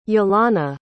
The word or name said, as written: yelana